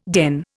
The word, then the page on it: den: Indian